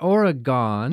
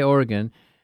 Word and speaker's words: Oregon: Oregon. Not Oregon. – Yes, like say it right. – Like you sometimes say here in Wisconsin. Coos Bay, Oregon.